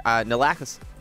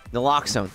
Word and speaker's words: Naloxone: Toronto high school students will be taught how to administer Naloxone. – Naloxone.